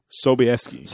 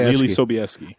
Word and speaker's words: Sobieski: How do you say her last name? – Sobieski. Lili Sobieski.